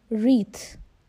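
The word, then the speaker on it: wreath: उसे कहते हैं रीथ, REETH, रीथ. यहाँ W साइलेंट है.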